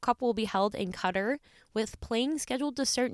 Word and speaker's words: Qatar: The tournament will be held in Qatar, spelled Q-A-T-A-R. Jacob just taught me how to pronounce that before the recording of this episode.